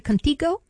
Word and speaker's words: Contigo: And you have a new song, am I saying it right, Contigo? – Yeah, you did great.